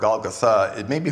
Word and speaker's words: Golgotha: Now, apparently, that is the more authentic way to pronounce that word. It's because very often words that you would see have the last syllable having the accent on them. So Golgotha, I'll try to say it that way. A lot of people just say Golgotha.